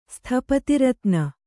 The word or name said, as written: ಸ್ಥಪತಿರತ್ನ